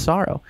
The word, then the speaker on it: $oro: Sorrow. It's like sorrow. Like, I feel lots of sorrow. But spelled with the dollar sign, R-O. Like, S-O-R-O.